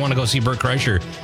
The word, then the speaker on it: Kreischer: And then we also have tickets to, I don't know how to say his name, Bert Kreischer? – Kreischer, yeah.